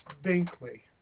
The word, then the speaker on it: Binkley: Dennis Binkley. – Could you spell that last name for me, please? – B-I-N-K-L-E-Y.